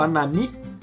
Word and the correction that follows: Hanani: Hanani, sei lá como é que se pronuncia. – Hanani. – É, Hanani. Hanani.